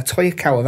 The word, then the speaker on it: Toyokawa: Yeah, Toyokawa, that's how you say his name.